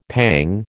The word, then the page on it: pang: American English